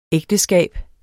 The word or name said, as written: ægteskab